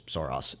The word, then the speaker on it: Soros: Soros is, of course, the British pronunciation of George Soros' name is Soros, and I jump between both, so I apologize to our listeners.